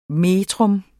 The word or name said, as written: metrum